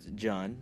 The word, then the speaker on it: John: So he's tries pronouncing my name. For example, he's like, Yun, Jun, Hyun. And I'm like, John. Yes, that's me.